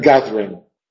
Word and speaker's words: gathering: También casi todas las versiones que se encuentran en el internet tienen gathering con un elipsis en la penúltima sílaba. Gathering, o sea, ga, primera sílaba, dring, segunda sílaba.